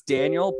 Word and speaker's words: Daniel: It's Daniel, but it's spelled D-A-N-I-E-L.